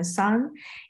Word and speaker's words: Sun: My name is Angelina Sun, and David is the first person to pronounce my last name the right pronunciation, because it is Sun in Chinese, and it just happened to be Sun in English.